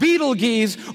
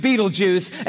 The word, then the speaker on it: Betelgeuse: It's called Betelgeuse or Betelgeuse. You can pick your pronunciation. I'm obviously going with Betelgeuse, And Betelgeuse is incredible.